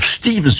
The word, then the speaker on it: Stevens: Een groep onder leiding van een zeker Geoff Stevens. Of Stevens, zo moet ik het uitspreken.